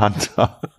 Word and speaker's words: Hanta: Hunter, so wie der Jäger auch, ne? ja, genau. so schreibt man Hunter. Hunter. H-A-N-T-A.